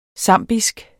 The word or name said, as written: zambisk